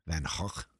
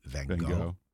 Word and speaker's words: Van Gogh: Which is more the Dutch pronunciation, The Dutch pronunciation is more like, and anyone that speaks Dutch out there will have a good laugh, but Van Gogh. You know, it's it has a more guttural thing.